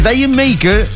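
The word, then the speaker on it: D'yer Mak'er: Y con la versión que hace Sheryl Crow del año 93 de la canción de Led Zeppelin del 73 llamada Dayer Maker, estamos abriendo esta segunda horita de este sábado 2 de mayo. Y tiene una historia bien interesante este Dayer Maker, porque Dayer Maker suena como Jamaica, como Jamaica.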